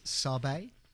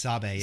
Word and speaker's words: Sabé: If I'm going to say the name correctly, it's Sabé. And this, Sabé, yeah.